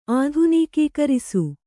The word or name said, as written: ಆಧುನೀಕೀಕರಿಸು